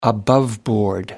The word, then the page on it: above board: British English